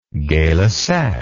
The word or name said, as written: gaylussac